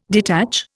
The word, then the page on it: detach: Indian